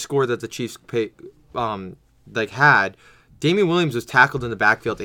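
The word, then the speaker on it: Damien Williams: Well, also, I really think Damian Williams, am I pronouncing it right? – Yes.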